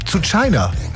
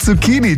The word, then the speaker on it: Zucchini: Sowas wie zu China. Ja, statt Zucchini, zu China eben.